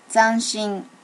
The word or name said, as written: zanshin